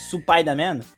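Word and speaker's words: Supai da Man (スパイダーマン): Lembrando, gente, Supai da Man é porque em Spider-Man, em japonês, a pronúncia é Supai da Man.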